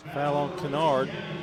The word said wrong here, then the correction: Kynard: I think I'm saying that wrong. Kynard, I believe, is how you pronounce that.